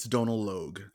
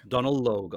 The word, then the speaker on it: Donal Logue: Donal Logie? – It's Irish, so I think it's Donal Logue, I think. – Donal Logue.